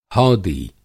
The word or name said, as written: हौदी